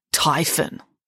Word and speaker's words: Typhon: And this Titan was called Typhon. What, like typhoon? asked Michael. Yes, said Nanny Piggins, except it's spelled with just one O.